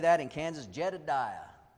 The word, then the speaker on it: Jedediah: Well, that's the English pronunciation. But look at your notes. I really found this interesting. I'm going to try it. You have the Hebrew there. It's said like this, Yedidiah, Yedidiah.